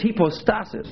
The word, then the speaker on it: hypostasis: the Greek term there is hypostasis.